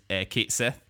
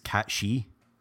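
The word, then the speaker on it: Cait Sith: That it's pronounced Cat She. – What? No way. – Yeah, man. Because it's actually, the name is based off it's Gaelic. It's based off a Celtic mythology, yeah.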